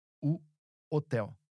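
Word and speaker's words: o hotel: And if you're looking for a hotel, the word is identical to the one in English, but pronounced slightly different. U hotel.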